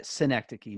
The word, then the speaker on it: Schenectady: And I can't pronounce the name right, but Schenectady, New York, is that how you say it? – Schenectady, New York. Yes, yeah.